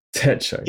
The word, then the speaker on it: Hobonichi Techo: Talk us through Hobonichi Techo. Am I saying that right? – Techo. Yeah.